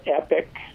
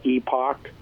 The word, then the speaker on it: Epoch: Who's doing a good job, and you can call it epic. That's how I pronounce it, or they some people call it epoch.